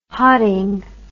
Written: hotting

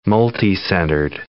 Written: multicentered

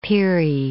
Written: Peary

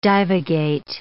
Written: divagate

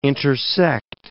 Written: intersect

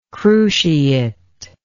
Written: cruciately

